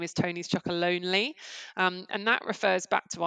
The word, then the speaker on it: Chocolonely: I know you've had recently some press coverage on the fact that lots of people think that you're called Chocolonely, Tony's Chocolonely, and they just read that name wrong. It's not right. It's Chocolonely.